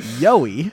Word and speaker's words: Yowie: It is Yowie. – I teach you so much. – It's Yowie and not Yowie. – Definitely not yokai. – Yowie. – It is spelled like Yowie. It's spelled Y-O-W-I-E. Yowie.